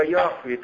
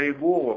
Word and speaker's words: Яхве: будем говорить, если в еврейском произношении, но оно очень трудное для нас, потому что там много горловых очень звуков, понимаете, там трудно выговорить. Я Яхве, то есть, или как потом в транскрипции не очень правильно передано, я Иегова, понимаете, да?